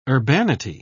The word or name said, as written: urbanity